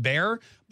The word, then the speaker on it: Bexar: It's Bear County, it's interesting because it's spelled B-E-X-A-R and it's pronounced Bear, but Texas is not spelled T, it's pronounced T's.